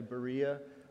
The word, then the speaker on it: Berea: And I want to talk to you about Paul's journeys, and I'm going to name, see where it says Beroia, that's actually Berea, up above Achaia in Greece, right there. That's Berea, so just that's good to know, even though it's spelled differently.